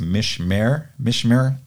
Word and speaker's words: mishmer: pronunciation of the Hebrew word is mishmer, M-I-S-H-M-E-R, mishmer, and it means to watch or to act like a sentry or like a post, to keep something safe.